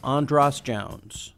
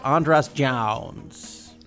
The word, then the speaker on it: Andras Jones: I am one of your hosts, and my name is Andras Jones. – And I'm Bryan Connolly, the other host. – I think I pronounced my own name kind of weird. I said Andras Jones.